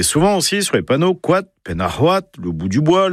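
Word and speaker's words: koad: Alors, pas coat, hein. Non, c'est quat. Même en français, quat, c'est ouaté, c'est le même mot pour la prononciation, c'est pas coat ni coat. Hein, on évite, on parle breton, pas grand breton. Quat, c'est quat.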